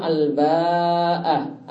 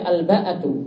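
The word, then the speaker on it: al-ba'ah (الباءة): Al-ba'ah di sini bisa ada empat cara baca. Ini disebutkan oleh Imam Nawawi dalam Syarah Sahih Muslim. Ada cara baca yang pertama al-ba'ah, ada mat di situ pada huruf ba'nya dan ada hak terakhir.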